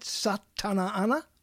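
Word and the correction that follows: Sa Ta Na Ma: Did I say it right? Tom? – What are you talking about? – That thing you did that you're Satana Anna? – Is it? Oh, Satanama. – Nama? – Satanama. – Yes, Satanama.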